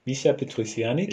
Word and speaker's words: Michel Petrucciani: Michel Petrucciani. – je le prononce bien, ça va Oui, oui, je pense.